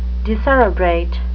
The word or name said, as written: decerebrate